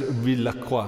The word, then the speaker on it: Rizla+: No, so it's actually should be pronounced Rizlacroix.